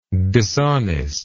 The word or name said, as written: dishonestly